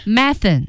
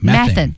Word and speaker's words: methane: Methane. – Yeah. – 啊，用的少。Methane. – Yeah. Methane. – 沼气， yeah. – 甲烷。 – British people say methane, and Americans say methane. So two pronunciations for that.